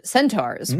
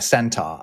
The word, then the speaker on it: centaur: Yeah, and I've noticed a transatlantic difference in pronunciation here because I've never heard an English person call it a centaur. I've only ever heard it as a centaur. – Centaur. I mean, that's how it's spelled, centaur. But we're lazy with our rhoticism.